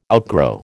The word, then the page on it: outgrow: American English